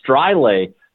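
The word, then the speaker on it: Streule: Yeah, I found that out with Max Struhl. That's who we had from the Winnipeg Ice during our broadcast this year, but it was Maximilian Strile, according to the Swiss pronunciation of his name.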